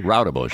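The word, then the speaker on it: Roudebush: Did I pronounce that correctly, MJ? Rautebusch. – Flash, right? You did. Perfect.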